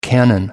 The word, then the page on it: canon: American English